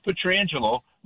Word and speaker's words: Pietrangelo: Like, how do you say the name?" He says, "It is Pietrangelo. My brother just always said it wrong."